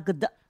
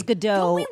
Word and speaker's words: Gadot: I thought it was Gadot. I thought the T was silent, and you're saying it's not.